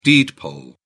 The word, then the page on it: deed poll: British English